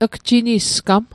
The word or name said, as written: gjinisgam